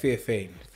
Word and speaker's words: Theophane: Theophane.